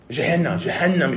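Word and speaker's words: Gehenna: Jehanna, if you want to pronounce it that way.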